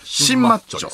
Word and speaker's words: 心マッチョ: 新マッチョと読むらしいです、